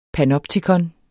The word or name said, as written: panoptikon